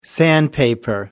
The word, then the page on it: sandpaper: American English